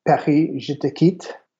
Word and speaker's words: Paris, je te quitte: So there's actually someone who started a site called, I'll say it correctly in French, it's Paris, je te quitte, which means Paris, I quit you, I'm leaving you.